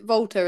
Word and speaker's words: Volta: I'm not started the Vault, is it Volta, isn't it? That's how you say it.